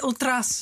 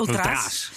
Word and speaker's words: Ultras: Ik hoor nu trouwens dat ze Ultra's zo uitspreken. – Ultra's.